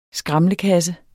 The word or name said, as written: SKRAMLEKASSER